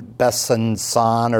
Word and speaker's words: Besançon: My sister tells me that C with a little funny hook on it is pronounced like an S. So I guess you would say Ben Besançon or something like that.